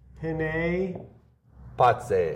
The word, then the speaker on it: Penne Pazze: It's not penne. It's penne pazze. – Penne. See, I try to pronounce, but I take too long on the pronunciation. – No. It's like it's penne. – Penne. – Penne. – Pazze. – Pazze. – Penne pazze. It's penne, one penne, word, pazze. Two capital P's.